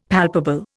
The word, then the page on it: palpable: Indian